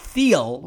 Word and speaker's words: Thiel: Some of you may not be, but it's Peter Thiel, T-H-I-E-L, not Thiel, which I incorrectly pronounced it at the beginning of my conversation with Max, but Peter Thiel, like the color.